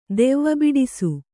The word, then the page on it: ದೆವ್ವಬಿಡಿಸು: devva biḍisu